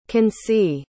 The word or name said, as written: Kancy